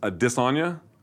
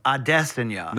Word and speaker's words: Adesanya: I'm told it's a disanya. – No, Adesanya.